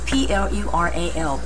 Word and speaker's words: plural: plural is very hard to pronounce because it has two l P L U R A L plural